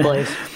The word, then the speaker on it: Blaise: We were reporting it as it's Blaise, right? I probably said his name wrong. My bad. – It is Blaise. – Blaise, I'm usually pretty good with the pronunciations, but I overthought that one.